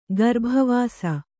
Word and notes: ಗರ್ಭವಾಸ: garbha vāsa

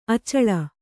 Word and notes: ಅಚಲೆ: acale

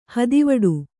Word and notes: ಹತ್ತೆವಿಡಿ: Kannada; hatteviḍi

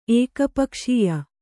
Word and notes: ಏಕಪಕ್ಷೀಯ: ēkapakṣīya

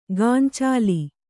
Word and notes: ಗಾಂಚಾಲಿ: gāncāli

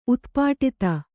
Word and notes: ಉತ್ಪಾಟಿತ: kannada

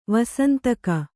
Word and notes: ವಾಸಂತಿಕ: vāsantika; Kannada

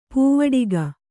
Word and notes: ಪಾವಟಿಕೆ: pāvaṭike

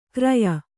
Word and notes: ಕ್ರಯ: kraya

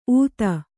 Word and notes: ಊತ: ūta

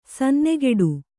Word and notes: ಸನ್ನೆಗೆಡು: sannegeḍu; Kannada

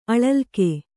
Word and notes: ಅಳಲಿಕೆ: kannada; aḷalike

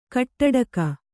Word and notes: ಗೋಟಡಕೆ: kannada

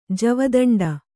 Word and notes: ಜವದಂಡೆ: javadaṇḍe

